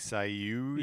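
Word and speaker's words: Sayu (声優): No, it's like Sayu.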